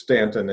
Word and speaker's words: Staunton: It's spelled like Staunton, but it's Stanton in Virginia.